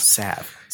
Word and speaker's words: salve: We looked up how to say it the right way, and it's Healing Salve. – So, you've already learned something. – Yes. Healing Salve. – Healing Salve. – Salve.